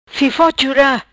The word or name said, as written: Fifo Chura